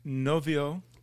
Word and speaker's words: Novio: Novio. – N-O-V-I-O.